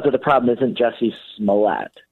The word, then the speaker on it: Smollett: How do you actually say his name? – Smollett. It's Jussie Smollett. – Smollett. – Smollett. – Smollett. – Smollett. Okay. – Smollett. – So it's yeah. – not a French.